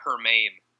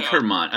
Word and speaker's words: German: His last name is spelled German, but this may be a Hermann situation. – Yes, could be a Hermann. Hermann. I think we should stick with Hermann for now.